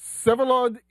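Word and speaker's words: Vsevolod: Sevalod Ischenko. – Yeah. There it is. The V is silent in the first name.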